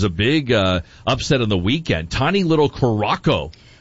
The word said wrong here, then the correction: Curaçao: Curaçao. I think it's how you pronounce that. – Oh. – Curaçao. – Is it? Yeah. Okay. Well, you would know better than me. – No, but maybe you would know better than me. I think it's pronounced Curaçao. – Okay. – Well, somebody I don't know. There's been a lot of debates about pronunciation. Yes, producer Will. – Confirmed, I've been there. It is Curaçao.